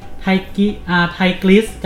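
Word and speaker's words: Tigris: ไทกริสกับยูเฟติส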